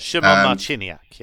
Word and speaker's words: Szymon Marciniak: The referee here is Szymon Marciniak. I pronounced that nearly correct. – Szymon Marciniak, yes.